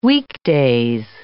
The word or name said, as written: weekdays